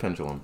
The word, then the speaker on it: pendulum: There's no G. Pendulum. – It's pronounced pendulum.